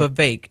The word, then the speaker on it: Vivek: Vivek. It rhymes with cake. Just Vivek.